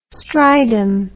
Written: Strijdom

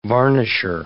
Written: varnisher

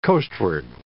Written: coastward

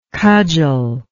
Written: cudgeler

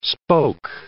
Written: spoke

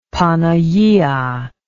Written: panagia

